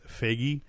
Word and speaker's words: Feige: Feige, and Nate, you know, I just want to say because I've heard it, his name pronounced a variety of ways. I'm going with Feige from now on because that's how they pronounce it at D23.